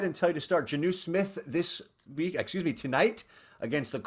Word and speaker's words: Jonnu: All right, standard league, Hunter Henry or is it Jonnu Smith? Is that how you say his name? – Yes, you got Jonnu Smith correct and go.